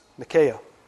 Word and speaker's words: Nike: The Greek way we pronounce Nike is Nikea or Nike.